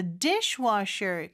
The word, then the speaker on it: dishwasher: So dishwasher 강세 주의해 주시기 바랍니다.